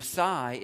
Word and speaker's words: psi: And then psi is, ps, it sounds like lips with PS.